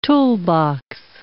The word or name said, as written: toolbox